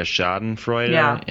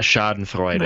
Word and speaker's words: Schadenfreude: I just felt so bad pronouncing it like that. I'm sorry, I can't do it. The German speaker, it hurts so bad. – Pronounce it in the German way. – A Schadenfreude. – There you go.